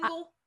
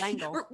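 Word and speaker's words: L'Engle: I think when I can't remember when, but some literature class or something, some teacher at some point in my life told me that if it's a, you know, if it's an apostrophe like that in a name, you just read it like, you say it like it's, the apostrophe's Lingle. Not there. Langle.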